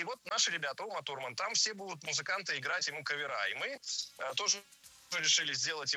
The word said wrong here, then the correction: кавер: Не кавера и каверами, а каверы и каверами. Ударение в слове «кавер» всегда остается на первом слоге.